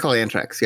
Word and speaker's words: Antrax: They call it Antrax, yes. So it's Anthrax. Just take the H away.